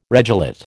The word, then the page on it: regelate: American English